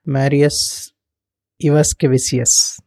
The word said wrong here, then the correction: Marius Ivaškevičius: Is that right? – That's a good attempt. It's such a long name, honestly. So it's pronounced Marius Ivaškevičius.